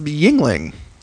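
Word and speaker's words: Yuengling: is Yingling. That's how you say it, even though it's spelled ridiculously.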